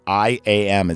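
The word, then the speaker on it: EYE AM: They're working on a new project called IAM. It's spelled in capital letters E-Y-E space A-M. So like I'm.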